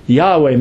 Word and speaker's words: Yahweh: So they come up with, if you try to force this translation, it comes out E-O-A, Yehovah. And that's an old English name for God, Yehovah, but it's a mispronunciation of the Hebrew. Just take my word for it. We believe the word was Yahweh.